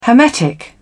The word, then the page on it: hermetic: British English